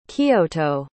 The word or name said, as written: Kiyoto